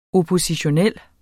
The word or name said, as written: oppositionel